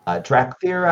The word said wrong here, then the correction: Dar'Khan Drathir: Uh, Dar'Khan Drathir. That's it, that's it.